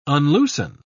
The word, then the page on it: unloosen: British English